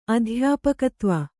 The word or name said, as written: ಅಧ್ಯಾಪಕತ್ವ